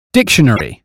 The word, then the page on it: dictionary: British English